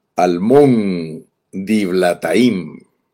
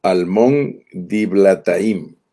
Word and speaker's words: Almón Diblataim: Y la jornada de hoy se llama Almón Diblataim, así leído en español. Almón Diblataim. Esa es la manera que se pronuncia en español.